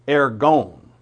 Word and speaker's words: ergon: ergon is what that should be. The O is always a long O in the Greek.